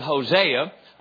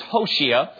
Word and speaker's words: Hosea: For instance, there's a book in the Old Testament that it was written by a man that we call him Hosea, but the Hebrew pronunciation of his name is Hoshea.